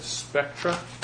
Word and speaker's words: Spectra: You said Spectra? – Correct. – How you spell that? – S-P-E-C-T-R-A.